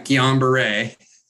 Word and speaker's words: Guillain-Barré: COVID vaccine causes the GBS, Guillain-Barré syndrome. – Yeah. Guillain-Barré.